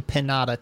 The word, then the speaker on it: Pinata: So you're the only Pinata Time. It's yeah, and some people will pronounce it like that, and I'm like, no, you know it's supposed to be pinata.